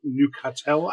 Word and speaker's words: Neuchâtel: He was the second child of Joseph Felicien Chevrolet, a watchmaker who lived in Canton of Neuchâtel.